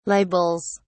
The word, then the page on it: libels: British English